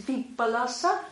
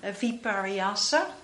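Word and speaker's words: vipallasa: So it's vipalasa with double L. And the Sanskrit version is viparyasa.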